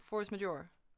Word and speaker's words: force majeure: Force major, force majeure.